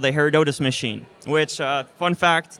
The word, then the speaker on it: Herodotus: Uh, when, how did you guys come up with the with the need for Her- Her- how you how, how you pronounce it? – Her- Herodotus. – Herodotus.